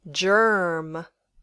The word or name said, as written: germ